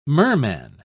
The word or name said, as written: merman